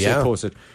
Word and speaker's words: Pulisic: So we're going to give away Pulisic. Am I saying it right? They say Pulisic. – You are to me, Pulisic.